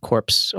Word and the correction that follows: Corps: Yeah, core, I'm hearing core, it like C-O-R-P-S-E. – Corpse. Is that how you say it? Core? Peace Corps? Peace Corps? – Yeah, man. Yeah.